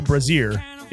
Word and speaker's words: Brazier: Did I say that right? – Yep, you did. But I never knew that. I never knew it was before. That was before. – Brazier. – It's like a bra.